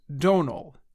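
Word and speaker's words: Domhnall: And in October, Donald Gleason jokingly called out television show host Stephen Colbert for saying his first name wrong. The right way to say it is Donald.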